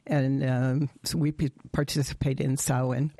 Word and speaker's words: Samhain: That's spelled Samhain, but it's pronounced Samhain.